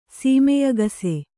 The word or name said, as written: ಸೀಮೆಯಗಸೆ